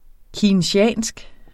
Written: keynesiansk

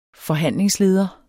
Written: FORHANDLINGSLEDERE

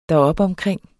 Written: deropomkring

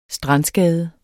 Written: STRANDSKADE

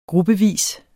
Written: gruppevis

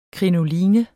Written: krinoline

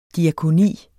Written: diakoni

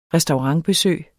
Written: restaurantbesøg

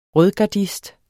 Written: rødgardist

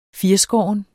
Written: firskåren

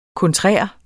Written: kontrær

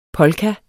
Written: polka

